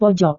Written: pojok

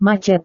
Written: macet

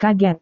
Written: kaget

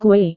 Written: kue

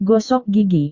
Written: gosok gigi

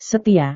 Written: setia

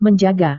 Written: menjaga

